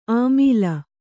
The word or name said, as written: ಅಮಿಲ್